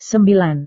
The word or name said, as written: sembilan